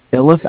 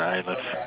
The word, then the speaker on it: Iliff: Are we 16701 East Iliff Avenue? – Yeah, it's you pronounce it Iliff. – Iliff, I-L-I-F-F, right? – Right.